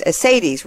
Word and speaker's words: Asaitis: We're always Asaitis, rhymes with Mercedes, she always told me, who has been on several of our shows this series, as well as our flagship series, Coffee Break with Game Changers.